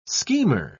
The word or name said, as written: schemer